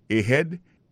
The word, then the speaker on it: ahead: Ahead. Ahead, là, c'est A-H-E-A-D. Ahead. Ahead, go ahead. Là encore, on a vu que accent tonique là tombé sur deuxième syllabe.